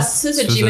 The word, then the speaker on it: Syzygy: Syzygy, mit einem harten S. Es wird englisch ausgesprochen und.